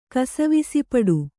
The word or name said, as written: ಕಸವಿಸಿಪಡು